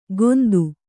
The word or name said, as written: ಗೋಂದು